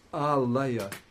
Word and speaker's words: Alaya: It should be a short A. Unless it was later corrected, I don't know. But it's Alaya.